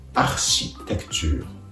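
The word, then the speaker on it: architecture: この単語は、綴り自体は英単語とほぼ同じなのですが、発音が家業、作業になるというところで全く異なりますので、読み方に気をつけてください。続けて発音すると、アシテクチュー、アシテクチューとなります。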